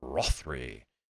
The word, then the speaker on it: Rothrie: Now, this is written by Sarah Rothry, I believe is how you pronounce that, R-O-T-H-R-I-E, Rothry.